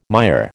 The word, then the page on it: mire: American English